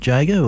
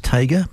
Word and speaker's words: Tjader: Cal Tjader, T-J-A-D-E-R. I believe it's pronounced Jager, or some people tell me it's Tager, but there you are, you have a choice.